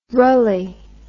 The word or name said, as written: rulley